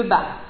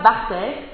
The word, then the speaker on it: Barthes: Vous avez peut-être déjà entendu de Barthes, qu'on ne prononce pas Barthès ni Barthès, de même qu'Albert Camus ne se prénomme pas Stéphane.